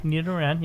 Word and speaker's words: Nidoran: I was saying it wrong earlier. So it's Nidoran? – Nidoran, yeah.